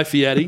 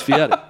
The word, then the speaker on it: Fieri: Guy Fieri. That's how you pronounce it. He pronounced it in the trailer that way. Yeah, it's spelled Fieri, but it's Fieri.